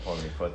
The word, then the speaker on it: Poirier: Yeah, Poirier.